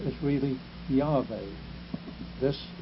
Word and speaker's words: Yahweh: Well, he was wrong in the first place, because the Jews don't have a hard J. They do have a J, but it's pronounced Yah. And if you're not sure about this, I'm just going to give you one instance. The Hebrew scriptures here and there say Hallelujah. Hallelujah. That means Yahweh. You see? Not Jeveh. Not Jehovah. It wouldn't have a hard sound.